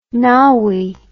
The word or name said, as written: naui